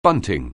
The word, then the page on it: bunting: anglais